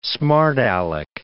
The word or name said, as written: smart alec